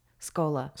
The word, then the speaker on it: Schola: Shkola Coffee. – Check out Shkola Coffee, the brick and mortar place. Check out their... – Shkola, not Shkola. I'm sorry. The CH threw me off. It's Shkola. – Shkola. – It's pronounced S-K-O-L-A. Shkola. My bad. And it's at Shkola, S-C-H-O-L-A, Coffee.